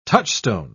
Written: touchstone

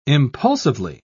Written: impulsively

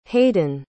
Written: haiden

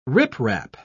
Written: riprap

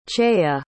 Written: chaia

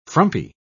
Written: frumpy

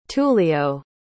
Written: tullio

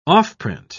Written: offprint

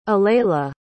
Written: aleli

